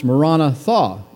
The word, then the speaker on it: Maranatha: If any man love not the Lord Jesus Christ, let him be anathema, Maranatha, or as it's pronounced, Maranatha in Aramaic.